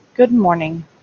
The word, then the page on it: good morning: English